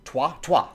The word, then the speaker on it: Trois: Troy? Trois? That's how you say it.